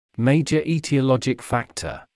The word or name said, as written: major etiologic factor